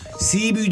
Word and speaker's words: Cebu: Cebu diye yazılır ama Sibiu diye telaffuz edilir.